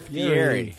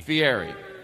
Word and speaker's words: Fieri: Fieri. – Fieri.